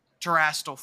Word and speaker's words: Terastal: Terastal forms. Pretty sure I nailed it there.